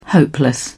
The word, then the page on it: hopeless: British English